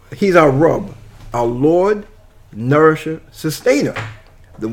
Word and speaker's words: Rabb: Okay, he is our Rabb, and that's spelled R-A-B-B, Rabb. And Rabb means the Creator, the Nourisher, the Lord, the Nourisher, Sustainer.